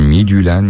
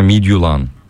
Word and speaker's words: Midtjylland: Yapay zekaya sordum, Midtjylland ya da Midtjylland şeklinde okunuyormuş diyor. Vallahi ilk başlarda herkes buna Midtjylland diyordu, tamam mı? Sonra Midtjylland oldu, sonra Midtjylland diye okundu. Evet, Midtjylland herhalde en doğrusu diye düşünüyorum. Midtjylland da fena değil yazıldığı gibi.